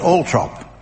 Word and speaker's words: Althorp: And Earl Spencer insists that his stately home, Althorp, is pronounced Althrop.